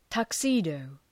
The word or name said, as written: tuxedo